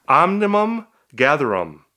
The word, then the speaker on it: Omnium Gatherum: Omnium Gatherum.